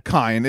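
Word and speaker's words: Kaine: Kine. It is Kine. – Yeah. – It is spelled C-A-I-N, so it's pronounced the Japanese way, but it is spelled like Cain.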